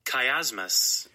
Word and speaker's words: chiasmus: Now, I have to really write it out like it sounds. Chiasmus. Because if I look the way it's spelled, I probably would never get it right. Chiasmus. It is spelled C-H-I-A-S-M-U-S. Chiasmus. It's spelled C-H-I-A-S-M-U-S.